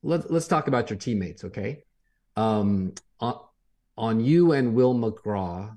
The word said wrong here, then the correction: McCraw: am I saying that right? McCraw, yes, sir.